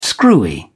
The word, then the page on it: screwy: British English